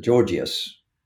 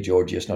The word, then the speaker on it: Gorgias: Now, that view of hell is most clearly expounded, and I talk about it in his Platonic dialogue called the Gorgias. I think it's a soft G, Gorgias, not Gorgias, but anyway, that famous Platonic dialogue.